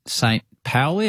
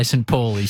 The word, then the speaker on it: St. Pauli: St. Pauli. Have I got the pronunciation right? – Yes. – Where you are now in Hamburg. Good, because I practiced that numerous times to not say St. Pauli.